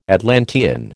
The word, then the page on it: Atlantean: American English